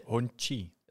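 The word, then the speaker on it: Hongqi: Honky. – Oh, sorry. – Zo spreek je dat uit. Is mij verteld.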